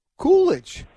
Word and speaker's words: Kulich: Think about Kelvin. It's Kulich. – Kulich. Oh, Kulich.